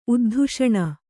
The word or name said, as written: ಉದ್ಧುಷಣ